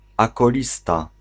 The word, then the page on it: acolyte: France; [a.kɔ.lit]